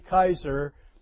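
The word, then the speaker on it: Keyser: Incidentally, if anybody is on Facebook and wants to keep in touch with us that way, my wife, Naomi Kaiser, is on Facebook.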